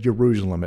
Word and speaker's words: Ierusalem: Yes, I said Ierusalem. It's spelled with an I.